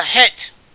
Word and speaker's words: Het: the words which are written C-H-E-T-H. This represents the Hebrew letter Het. Het, which is pronounced in a way which is no real equivalent in English, though in Scottish and in some European dialects there are equivalents for it, or rough equivalents for it, possibly derived from it.